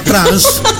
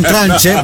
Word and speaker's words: tranche: Vado a trance? – No, vado in tranche? – Vado in tranche? – In tranche, cioè si scrive...